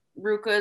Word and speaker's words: RVCA: So RVCA or Ruka is how, you know, I guess it's pronounced,